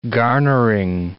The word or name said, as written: garnering